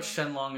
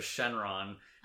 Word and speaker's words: Shenron: Shenlong, Piccolo Jr. I don't know why I wrote Shenlong instead of Shenron. Shenlong is how you would pronounce it if you took the same characters and said them in Chinese.